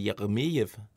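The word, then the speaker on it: Jeremejeff: Han skal formentlig udtales mere russisk, end det vi har gang i. Øh, han har undret sig over vores udtal, og derfor hørte han lige nogle svenskere på arbejdet, hvordan man udtaler navnet. Det er altså, skal udtales russisk, så det er Jeremejev. – Nej. EF. Ej, der øh, står jo Jeff. der. Altså e J-E-F-F.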